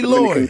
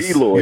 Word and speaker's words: Eloy: Well, you know, I can't pronounce these guys' names, man. These Eloy. – Lysoras. – Eloy. You're going to make a great GM one day.